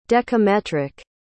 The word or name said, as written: decametric